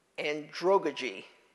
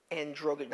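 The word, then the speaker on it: andragogy: I'm going to pronounce this the best I can, androgogy. Androgogy, not androgyny, androgogy.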